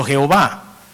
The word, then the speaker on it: Jehová: Entonces vino alguien y trató de armar una palabra metiéndole unas vocales de manera que pudiésemos pronunciarlo. Por eso Yahvé y por eso Jehová. Es lo mismo. Puede ser Yahvé o puede ser Jehová.